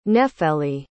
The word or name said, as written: nefeli